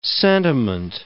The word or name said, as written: sentiment